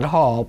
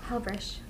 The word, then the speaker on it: Halbreich: Hal. But I do it, some Hal. People call it Hall, but I say Hal. – Hal. – It's Midwestern. – Right. – But my husband pronounced it Halbreich.